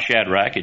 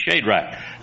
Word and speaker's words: Shadrach: He said it's not Shadrach, it's Shadrach. Now you can check that. I don't know how your Bible pronounces it, but Brother Plum always said Shadrach.